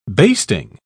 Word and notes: basting: British English

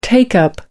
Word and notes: take up: American English

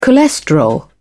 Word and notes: cholesterol: British English

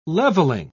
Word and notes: leveling: British English

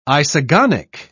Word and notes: isogonic: British English

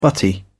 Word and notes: butty: British English

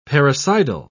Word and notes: parricidal: British English

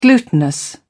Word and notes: glutinous: British English

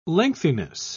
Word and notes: lengthiness: British English

